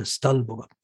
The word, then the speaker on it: Stolbova: Då träffas man i den lilla byn Stalbova. Jag har faktiskt alltid sagt Stolbova, men nu har jag mina rysslandskunniga vänner lärt mig att man ska betona första stavelsen istället. Och lite A-ljus här, Stalbova.